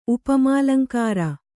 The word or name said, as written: ಉಪಮಾಲಂಕಾರ